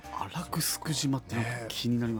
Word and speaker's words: 城: 沖縄城と書いてグスクってね、読むパターンよくありますけどね。